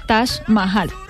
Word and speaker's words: Taj Mahal: Se escribe T-A-J y Mahal, M-A-H-A-L. Taj Mahal, así se pronuncia.